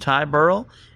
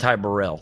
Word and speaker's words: Ty Burrell: And Jennifer Aniston, along with Ty Burrell. – Oh, wow. – And Ty Burrell, excuse me.